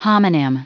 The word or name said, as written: homonym